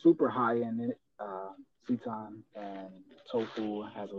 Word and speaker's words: seitan: Something I love is called seitan. I think it's actually pronounced Satan, but I just say seitan.